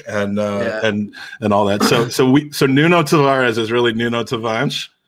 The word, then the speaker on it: Tavares: Anyway, Nuno Tavares. Is that how you say his name, Tom? – Tavares. – See, but before we move on to transfer. It's Nuno Tavares.